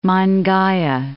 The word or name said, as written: Mangaia